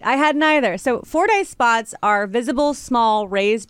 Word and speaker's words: Fordyce: just be aware of is Fordyce spots. Did I say it correctly? You did.